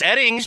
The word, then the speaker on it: Oetting: Mark Oeding. – Hold on. It's pronounced Edding. – But it's spelled O-E-T-T-I-N-G. – Yes, and O-E-T-T-I-N-G. It's pronounced Edding.